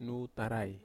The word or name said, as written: nutarai